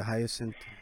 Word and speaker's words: hyacinth: All right, so the next example, the water hyacinth. Am I saying that right? The hyacinth. – Yeah, yeah, yeah.